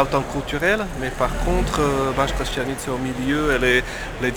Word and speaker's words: Banská Štiavnica: Banská Štiavnica.